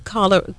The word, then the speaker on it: cholera: And they were talking about Zimbabwe and the outbreak of cholera. Did I say it right? C-H-O-L-E-R-A. – Yeah. – Cholera.